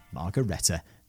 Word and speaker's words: Margaretta: I'm just going to check the pronunciation on the main subject's name. Margaretta. Nailed it.